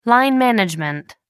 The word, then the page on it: line management: American English